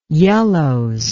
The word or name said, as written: yellows